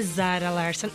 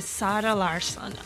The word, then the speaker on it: Zara Larsson: Eu digo Sara Larsson porque lá na língua oficial da Suécia, né, a língua deles, eles não pronunciam o Z como Z. Eles pronunciam Z com S. Então não é Zara Larsson, é Sara Larsson.